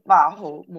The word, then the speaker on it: 魔法: 马猴是魔法哦，好像是日语发音，就是那个马猴魔法。